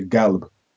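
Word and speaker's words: qalb (قلب): So the Arabic word for heart, which is qalb, they wouldn't say qalb, they would say galb.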